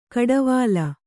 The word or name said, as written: ಕುಡಿವಲೆ